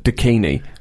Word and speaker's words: DKNY: It's spelt D-K-N-Y, but it's pronounced Dakini, which is really something.